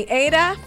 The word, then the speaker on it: Aida: I'm Funny Ada, Funny A-I-D-A. And I say it that way because that's how you spell it.